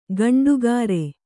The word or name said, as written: ಗಂಡುಗಾರೆ